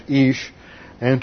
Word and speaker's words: isha: And in the Old Testament, the Hebrew word for man is ish. And to form the word for woman, they added the feminine ending to it, which is isha.